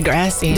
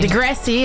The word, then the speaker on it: Degrassi: Degrassi? – Degrassi. – Degrassi, ja.